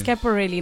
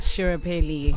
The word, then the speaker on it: Schiaparelli: Yes, it's Schiaparelli, not Schiaparelli.